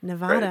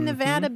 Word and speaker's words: Nevada: I grew up in Kentucky, and I have to confess that I pronounced it like your friends in North Carolina, Nevada. – Right. – I never knew anything different until just a few years ago, and now I'm training myself to say Nevada because I've run into so many people from that state who are very, very insistent that it's a short A.